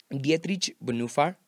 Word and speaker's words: Dietrich Bonhoeffer: Um, Dietrich Bonhoeffer así se pronuncia en, alemán.